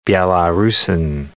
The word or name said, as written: Belarusan